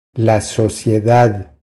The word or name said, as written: La sociedad